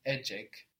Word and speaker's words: ecek: This is spelled E-C-E-K. Or A-C-A-K. So we see that the C in Turkish makes a J sound, J.